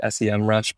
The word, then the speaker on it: SEMrush: So, as far as just like an overall SEO tool, either you got your Ahrefs or your SEMrush I think it's SEMrush now. It used to be like the capital S E M and then Rush. I'm sure everyone pronounced it S E M Rush, but it's kind of like one of those brands that now all of a sudden they, they call it SEMrush.